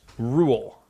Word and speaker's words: Roald: It's actually pronounced Rule. – Ruled. – Ruled Dahl. – Yeah, the D is silent.